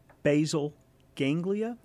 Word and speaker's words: basal ganglia: Basal ganglia? – Yeah.